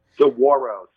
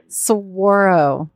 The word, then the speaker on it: Saguaro: Saguaro, soft G. – Soft G. Okay. Okay, everyone. – Yep. – We got it. – Sanction. – Saguaro.